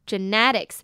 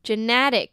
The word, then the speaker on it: genetic: genetic 呢指的是起源的发生的或者是基因的 genetics 那它的重音呢是在 ne 的地方 ge呢是发ge的音 ne 的e呢则是发微笑的嘴型 genetic